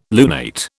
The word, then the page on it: lunate: British English